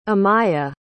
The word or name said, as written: amaya